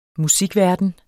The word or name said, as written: musikverden